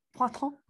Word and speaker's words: Poitou: So Poitou, maybe? – Poitou. – Oh, sorry, French people.